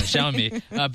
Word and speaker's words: Xiaomi: Yeah, Hugo Barra is leaving Google, and he's heading to, is it Xiaomi? Is that how you pronounce this? – That's how I pronounce it. – Well, we'll be consistent. Xiaomi.